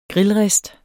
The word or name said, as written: grillrist